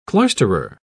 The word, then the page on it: cloisterer: British English